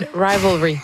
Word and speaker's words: Heated Rivalry: Et évidemment, on a beaucoup parlé dans la dernière année grâce à la trame sonore de Hated Rivalry, mais... Elle est bien prononcée, je pense, c'est la première fois. – Non, Heated Rivalry.